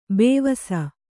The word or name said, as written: ಬವಸು